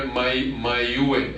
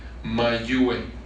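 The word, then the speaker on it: Mayhue: Richard Mayhew, se escribe Mayue, M-A-Y-H-U-E, e, Mayue, con H después de la Y, Mayue, como Mayugar, pero May, h u e, Mayue, ¿ok?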